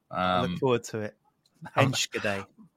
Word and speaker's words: Enschede: But I did have a bit of research into it, and apparently it's Enschede.